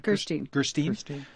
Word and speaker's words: Gerstein: Gerstein, yeah.